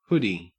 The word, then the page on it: hoody: Australian English